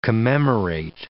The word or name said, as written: commemorate